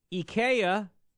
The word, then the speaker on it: IKEA: IKEA. Yeah, that's how it's pronounced.